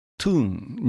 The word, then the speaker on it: town: それから 2 番の coming from the town、 coming from the town のことなんですが、 from をスコットランド方言では fray と発音し、 town が toon になります。